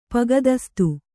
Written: ಪೊಗದಸ್ತು

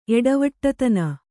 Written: ಎಡವಟ್ಟುತನ

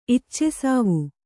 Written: ಇಚ್ಚೆಸಾವು

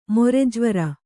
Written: ಮೊರೆಜ್ವರ

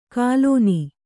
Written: ಗುಲ್ಲನೆ

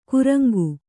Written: ಕೂರಂಗಿ

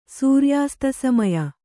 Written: ಸೂರ್ಯಾಸ್ತಸಮಯ

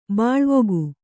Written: ಬೆಳ್ವಕ್ಕಿ

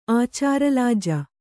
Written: ಆಚಾರಲಾಜ

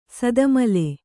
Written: ಸೇತಮಾಲೆ

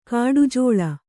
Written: ಕಡುಝಳ

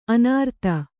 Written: ಅನೂರಾಧ

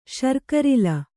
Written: ಶರ್ಕರಿಲ

